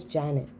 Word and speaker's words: Jannet: For example, as I mentioned earlier, my name is Janet. It's spelled J-A-N-N-E-T.